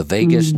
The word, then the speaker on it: vagus: Is it vagus? Vagus nerve? Okay, I said it right then.